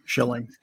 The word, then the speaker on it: Schilling: Did I get your last name right? It's Schilling. – Correct. Schilling. – I mean, I don't know how else you would pronounce that, but you never know. Schilling or something. – Every once in a while, it's Chilling.